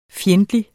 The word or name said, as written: fjendtlig